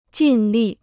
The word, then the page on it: 尽力: jìn lì